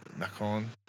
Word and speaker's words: Macron: And of course, that was Emmanuel Macron that, if I said that right, and Macron. – You said it beautifully.